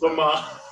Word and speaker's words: Lamaze: Pre-game, I don't know if I'm pronouncing this right, Lamaze classes in center field. – Lamaze, I believe it's Lamaze.